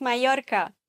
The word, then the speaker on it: Mallorca: Genauso übrigens Mallorca. Mallorca. Nicht Mallorca und auch nicht mal Lorca, Mallorca könnte man im argentinischen Spanisch sagen. Mallorca. Aber Castellano, Mallorca.